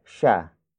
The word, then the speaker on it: siya: Tumakbo is spelled as T-U-M-A-K-B-O. It means ran, as in the process of running has been accomplished. After which we have sha, sha, sha. Sha is spelled as S-I-Y-A.